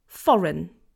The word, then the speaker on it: foreign: Foreign. So it's not foreign, okay? It's just foreign. Foreign. One more time. Foreign. The stress is on the first syllable.